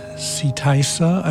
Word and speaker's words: Seetysa: Setysa, I believe is the pronunciation, S-E-E-T-Y-S-A, from the album Lagoon, released stateside on Hearts of Space Records around 1990 or thereabouts.